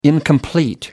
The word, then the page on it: incomplete: American English